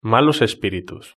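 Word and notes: malos espiritus: Spanish (Spain)

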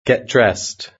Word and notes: get dressed: British English

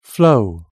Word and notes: flow: British English

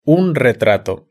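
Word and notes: un retrato: Spanish (Spain)

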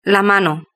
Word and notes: la mano: Spain Spanish

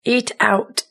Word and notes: eat out: British English

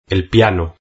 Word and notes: el piano: Spain Spanish

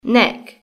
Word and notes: neck: British English

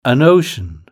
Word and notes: an ocean: British English

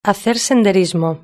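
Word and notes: hacer senderismo: Spanish (Spain)

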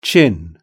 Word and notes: chin: British English

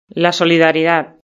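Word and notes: la solidaridad: Spanish (Spain)

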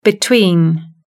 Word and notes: between: British English

